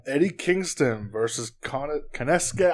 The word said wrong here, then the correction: Takeshita: I will never fucking pronounce it. Takeshita. I just call him Takeshita. Takeshita.